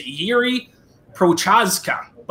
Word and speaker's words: Jiří Procházka: Jiří Procházka.